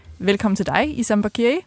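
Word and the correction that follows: Bachiri: Jeg vil bare sige, det udtales Bashiri. – Bashiri. – Ja, det er fordi, det er en fransk ting.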